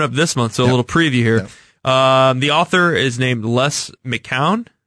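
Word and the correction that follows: McKeown: Is that how you say it? – McKeon.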